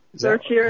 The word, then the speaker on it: certiorari: It's a petition for a writ of certiorari? – Certiorari, how do you pronounce it? – Yeah. Certiorari. That's for the Supreme Court.